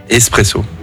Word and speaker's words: espresso: Espresso. – Espresso, ouais. Étymologiquement, on ne prononce pas le X. L'origine du mot vient de l'italien esprimere.